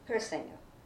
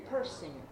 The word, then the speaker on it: Persinger: No, Persinger. – I spell it. – I don't know that. – Persinger. – Mm-hmm. – P-E-R-S-I-N-G-E-R. – Oh, Persinger.